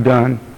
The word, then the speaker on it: Donne: His name is Donne, D-O-N-N-E, but it's pronounced Donne.